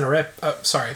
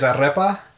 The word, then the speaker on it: arepa: So, the last one is an arepa. Pronunciation. – So, yeah, pronunciation there is arepa. – Okay. Arepa.